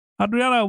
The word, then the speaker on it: Adriana: Adriana, welcome to the MarTech Podcast.